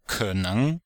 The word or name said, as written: ke3neng2